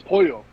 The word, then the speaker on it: Pollo: It's Pollo. – Pollo. – No. – L's are Y. – Yes. – L's are Y. Yeah. – Say it again. – I believe. Yeah. – El Pollo Loco.